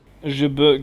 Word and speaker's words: JB: ich weiß nicht, was J, wie JB auf Französisch ausgesprochen. Jebe? Jebe, genau, von Jebe.